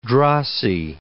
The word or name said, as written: drossy